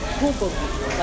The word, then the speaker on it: bulgogi: So it's B-U-L-G-O-L and, like, K-I-G-I, bulgogi. – Okay. That sounds like a made-up word, you know? – But it's, like, the way you have to say it, like, I said it wrong. Like, I can't just say bulgogi. It's, like, bulgogi. Like, there's an airiness to the B.